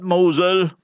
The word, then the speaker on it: Mosul: If you hear the Westerners and them saying, they say Mosul, M-O-S-U-L. We must get out of this habit, pronouncing the ways of the Kuffar, Gaza, nay, Gaza. And remember, not Mosul, Mosul.